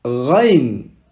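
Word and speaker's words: Rain (غين): Rain. Ici, pour prononcer, une petite astuce, c'est comme si on avait de l'eau au fond de la gorge. Rain.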